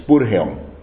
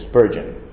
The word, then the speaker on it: Spurgeon: El notable predicador, ministro bautista, Charles Haddon Spurgeon, aquí se pronuncia Spurgeon, es lo mismo, la pronunciación en inglés es Spurgeon,